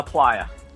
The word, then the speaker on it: Plaia: Sure, it's Plaia.